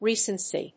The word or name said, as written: recency